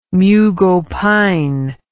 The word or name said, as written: mughopine